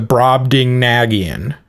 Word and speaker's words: Brobdingnagian: and the Brobdingnagian.